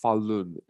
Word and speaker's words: Falun: It's pronounced Falun, F-A-L-U-N, and the next word is G-O-N-G.